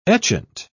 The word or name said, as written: etchant